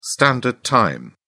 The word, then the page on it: standard time: British English